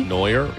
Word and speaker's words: Neuer: Neuer for Germany. – Neuer, yeah.